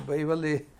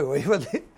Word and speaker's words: Waverley: Ja, die waren Zeitgenossen und Waverley, angeblich ist die richtige Aussprache von Waverley, aber ich bleibe bei Waverley, ob Sir Walter Scott der Autor von dem Roman Waverley ist.